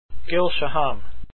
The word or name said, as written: shaham